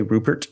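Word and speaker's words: Ruppert: The Pollux is made by Mobius and I want to say Rupert, even though it looks like it's spelled Rupert, two P's.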